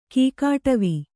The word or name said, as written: ಕೀಕಾಟವಿ